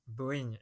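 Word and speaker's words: Bohinj: Il y a aussi le, y a le lac de, de Bled, il y a le lac de Bohigne. – Bohigne, exactement, chercher comment le prononcer.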